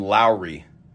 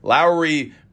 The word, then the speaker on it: Lauri: Although the Cavs announcer pronounced him Lowry, so I don't care. Lauri Markkanen.